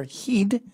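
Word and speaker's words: heid: I believe the Glaswegians use their heed, as it's pronounced, more than their fists, and just destroys not just the man's nose, but his entire facade.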